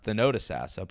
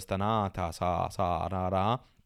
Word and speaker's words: Thonotosassa: And also, I have some fun trying to pronounce his hometown, which I now know is pronounced Thonotosassa, but I thought it was Thonotosassa.